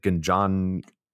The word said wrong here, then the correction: Ginjinha: I think it's Ginjinha. – Ginjinha, the cherry liqueur that Mike was talking about from Portugal.